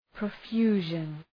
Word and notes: profusion: prə’fju:ʒən

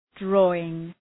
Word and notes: drawing: ‘drɔ:ıŋ

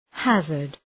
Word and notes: hazard: ‘hæzərd

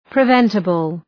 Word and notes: preventable: prı’ventəbəl

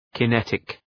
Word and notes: kinetic: kı’netık

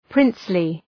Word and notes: princely: ‘prınslı

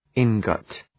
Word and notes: ingot: {‘ıŋgət}

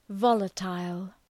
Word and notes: volatile: ‘vɒlətəl

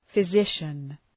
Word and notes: physician: fı’zıʃən

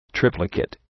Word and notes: triplicate: ‘trıplə,keıt

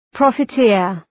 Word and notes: profiteer: ,prɒfı'tıər

